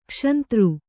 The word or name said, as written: ಕ್ಷಂತೃ